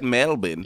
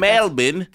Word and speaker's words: Melbourne: Melbourne. – No, but... – Melbourne. – That's Melbourne.